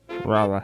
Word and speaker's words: Rolla: And then new settlers from North Carolina voted to name the city after Raleigh, their hometown, but chose to spell the Missouri version phonetically. – Rolla. – Rolla. – Rolla. – What? – Roll-A. – Roll-A. – Roll-A. Rolla. It's so, Raleigh. It's Raleigh spelled phonetically.